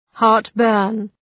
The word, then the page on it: heartburn: ‘hɑ:rt,bɜ:rn